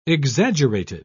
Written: exaggerated

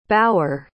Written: bauer

